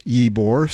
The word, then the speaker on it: Ybor: Ybore, it's pronounced Ybore, spelled Y-B-O-R, and stay tuned to find out why that name's important.